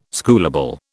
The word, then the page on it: schoolable: British English